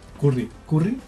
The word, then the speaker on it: Curry: Curry. – ¿Se pronuncia Curry? Curry. Sí.